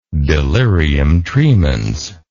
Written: delirium tremens

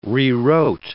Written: rewrote

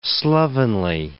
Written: slovenly

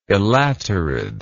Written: elaterid

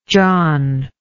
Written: jon

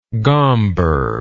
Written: gomberg

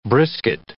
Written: brisket

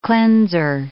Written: cleanser

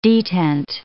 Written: detent

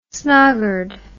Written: snoggered

